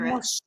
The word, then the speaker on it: Moshe: And oh, will you say his name in Hebrew for us so people could hear Moshe.